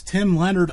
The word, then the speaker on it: Tim Leonard: My guest tonight is Tim Leonard of Leonard Motorsports Marketing.